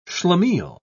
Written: schlemiel

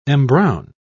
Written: embrown

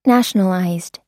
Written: nationalized

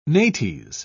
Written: nates

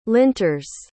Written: linters

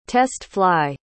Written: test-fly

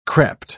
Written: crept

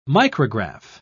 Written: micrograph

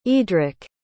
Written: eadric

